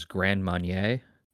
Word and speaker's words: Grand Marnier: The first time I met Allie, my partner's family, we went to this restaurant, and their whole thing was Grand Marnier. Am I saying it right? – No. – It's French.